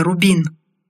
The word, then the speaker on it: Рубин: «Что она умеет?» Многое, Рубин. Ударение на «у» — это моя фамилия.